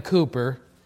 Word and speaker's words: Cowper: Anyway, William Cooper, which is spelled C-O-W-P-E-R, so the way we pronounce it.